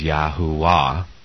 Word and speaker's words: Yahuwah: The best that I can do is to pronounce his name phonetically as Yahuwah.